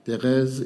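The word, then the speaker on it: Thérèse: Therese is the French pronunciation of Teresa.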